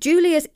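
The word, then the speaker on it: Giulia: So this is hashtag Julia's Eco Shell. I do hope I'm pronouncing that right because it is Italian, but I have Italian friends called Julia and that's how they pronounce it.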